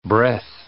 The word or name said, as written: breath